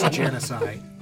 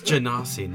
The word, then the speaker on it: Genasi: It's Janisai. – Janisai? – Janisai. I'm just mocking my poor pronunciation that you guys named the episode after. – Yeah, we had that. It's Janasi, not Janisai.